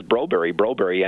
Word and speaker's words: Broberg: When we were listening on to all the Swedish broadcasts from Sweden last year of his games, everyone says Broberg, and that's how you pronounce it in Sweden.